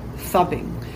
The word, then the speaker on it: phubbing: It's spelled P-H-U-B-B-I-N-G, fubbing.